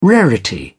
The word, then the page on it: rarity: British English